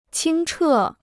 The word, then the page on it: 清澈: qīng chè